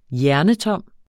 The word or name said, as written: hjernetom